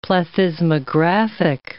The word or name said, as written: plethysmographic